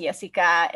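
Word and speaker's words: Yesika: So on Twitter, I'm at Orvas Jessica, and the first name is actually spelled differently.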